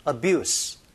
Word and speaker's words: abuse: abuse, 명사니까 z가 아니고 스 발음인데요.